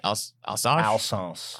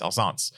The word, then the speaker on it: Alsace: How do you pronounce that? A-L-S-A-C-H? – Alsace. – Yes, Alsace.